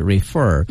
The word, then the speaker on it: refer: refer 发音是 refer，嗯，它的重音是在第二个音节。